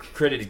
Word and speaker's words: Critides: Wade, I may get your last name wrong. Critides? – Critides, yeah, it's Greek. – Critides, Greek.